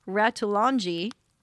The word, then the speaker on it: Ratulangi: The container ship named Sam Ratulangi, not Ratatouille. – That's another good job with your pronunciation.